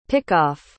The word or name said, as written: pickoff